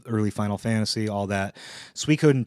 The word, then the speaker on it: Suikoden: Suikoden, somebody corrected me. I used to call it Suikoden, like when you call a pig. Sui, like, Sui, get over here, pig. I guess it's pronounced like Sui. Like as if you were saying, like, sweet without the T, so like Suikoden.